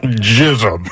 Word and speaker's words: Chisholm: At least wasn't like rushing through it or slurred his way through it. He pronunciated that bad boy. – Chisholm.